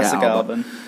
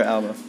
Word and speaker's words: Alba: It was just Jessica Alba. Or Alba. – Yes. You get her name right, motherfucker.